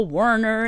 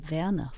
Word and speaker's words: Werner: And some would say, oh, Werner, and make it, you know, sound very American. Right. And others would try very diligently to say Werner von Trapp.